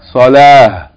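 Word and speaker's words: Salah (صلاة): Salatun. – Salatun. If we stop on this word, we pronounce it? – Salah. – Salah. Like ha on the end, without any vowel.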